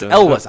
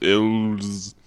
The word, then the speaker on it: Elwes: So it's Elwes. I never remember how to pronounce his last name. So you could probably also go with Elwes, but that's wrong.